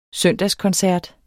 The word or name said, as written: søndagskoncert